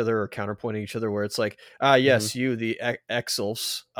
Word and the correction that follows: Exif: I'm completely butchering these names, the Exilfs. – Exifs. – Exifs, thank you.